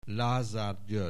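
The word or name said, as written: Lázár György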